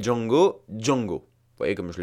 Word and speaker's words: Django: Et en version française, de même, pendant tout le film, on l'appelle Django. Pourquoi tout simplement parce que la langue anglaise veut que les mots commençant par J se prononcent J.